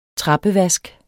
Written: trappevask

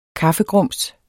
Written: KAFFEGRUMS

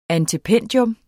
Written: antependium

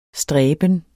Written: stræben

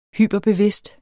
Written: hyperbevidst

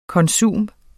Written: konsum

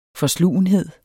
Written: FORSLUGENHED

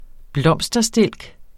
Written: blomsterstilk

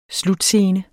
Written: slutscene

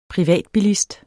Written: privatbilist

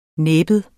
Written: næbbet

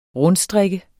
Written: rundstrikke

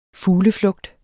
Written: FUGLEFLUGT

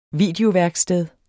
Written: videoværksted

